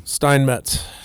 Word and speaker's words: Steinmetz: Steinmetz.